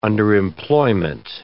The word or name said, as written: underemployment